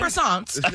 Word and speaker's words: croissants: Like the big, like, how do you say it again? – Croissants. – Yeah, you really enunciated that.